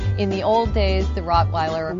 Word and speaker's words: Rottweiler: The Rottweiler is an amazing dog, and actually, if you want to be really technical, because it is a German breed, the W is pronounced as a V, like Victor, so it's Rottweiler.